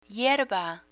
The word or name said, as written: hierba